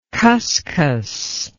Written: khuskhus

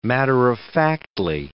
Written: matter-of-factly

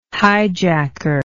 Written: highjacker

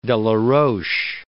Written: Delaroche

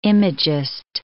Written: imagist

